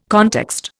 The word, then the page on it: context: Indian